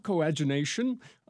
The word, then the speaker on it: coadunation: Coadunation is the next word, if I pronounce that properly. – That's close enough. Coadunation, that is the union as of dissimilar substances in one body or mass.